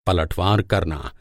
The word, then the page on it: पलटवार: Hindi